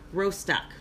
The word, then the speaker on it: Rostock: The incident took place on Monday evening in the coastal city of Rostock.